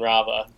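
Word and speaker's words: Raava: He's the only character that pronounces both A's and Rava. – Rava. – He definitely lets it linger, yeah.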